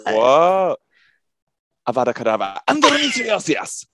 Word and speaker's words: endometriosis: And other than when I briefly fell into like a TED Talk vibe about endometriosis, whoa, I didn't even say that right, about endometriosis. – Endometriosis.